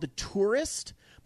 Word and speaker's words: Touryst: And then a new game that I checked out this week was, thanks to Xbox Game Pass, they just added a game called The Tourist, but it's spelled, it's like T-O-U-R-Y-S-T or something. It's spelled differently.